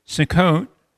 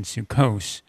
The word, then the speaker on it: Sukkot: Sukkot, in Hebrew, commonly translated the Feast of Tabernacles or the Feast of Ingathering, and traditionally the Ashkenazi pronunciation Sukkos literally means Feast of Booths.